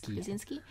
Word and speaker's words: Krasinski: John Krasinski. – Krasinski.